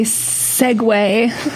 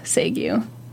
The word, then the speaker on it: segue: Did you learn how to say the word segue? – I said that on purpose, just for you. – Aww. – But I know that the correct way to pronounce it is segue. – It's not. – That's how it's spelled. – Not correct. – That's how it's spelled. – Do you have trouble with a lot of French-derived words? – Well, I don't speak French, so.